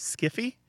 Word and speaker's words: sci-fi: They intentionally coined it to sound like the word hi-fi. But the truth is, there's actually two other things happening with sci-fi. It's a weird one. Some people jokingly pronounce it as skiffy, especially if they're talking about bad science fiction.